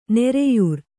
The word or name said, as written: ನೊರೆಯಾರು